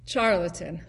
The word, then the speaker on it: charlatan: He was... Charlton? Like a faker, a person... Yeah, how do you pronounce it? – Charlatan.